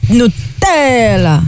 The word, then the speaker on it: Nutella: Nutella. – Nutella. – Nutella. – Nutella. – Nutella. Faut mettre l'accent sur le T. – Ouais. – Nutella.